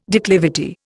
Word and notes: declivity: Indian

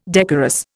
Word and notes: decorous: Indian

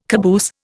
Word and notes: caboose: Indian

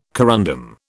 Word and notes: corundum: British English

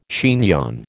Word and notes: chignon: American English

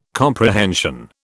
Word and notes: comprehension: British English

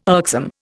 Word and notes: irksome: Indian

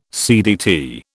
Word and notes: CDT: British English

